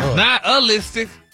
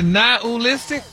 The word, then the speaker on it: nihilistic: But you actually pronounced it correctly the last time you said it. – Really? Nihilistic? – Wow. – Stop putting the ooh in there.